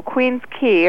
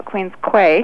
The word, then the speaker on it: Queen's Quay: If you want to stay more central, though, Queen's Quay, or most out-of-town people call it Queen's Quay because of the way it's spelled, it's the most southern part of Toronto.